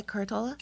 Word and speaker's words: Curatola: Curatola? – Curatola.